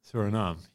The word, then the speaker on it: Suriname: Is it Suriname? Suriname.